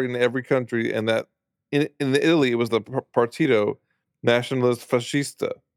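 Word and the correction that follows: Nazionale: I totally just said that wrong. Nacionale Fascista. I can't speak Italian, folks.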